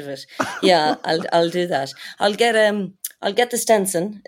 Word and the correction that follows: Stetson: Yeah, I'll get the Stetson and I will be living it up.